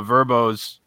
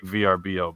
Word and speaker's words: VRBO: Now, so because if you look at some of like the Verbos, by the way, I've started seeing commercials for VRBO and they pronounce it Verbo. – Oh, they are Verbos, huh? – Which I don't like. I like VRBO better.